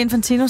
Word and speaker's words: Infantino: Det jeg har udtalt navnet rigtigt, alt muligt. – Det gjorde du. Det er nemlig Infantino, som har fået nogle, som de skriver ind på DR.dk, ordentlige krasmærker i lakken i løbet af det seneste uge.